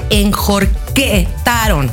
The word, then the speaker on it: enjorquetaron: Te voy a decir que se dice enjorquetaron. – Ah, enjorquetaron. – No lo digo yo, lo dice Norca. – Ah, bueno, sí. Enjorquetaron. Lo he dicho mal todo este tiempo.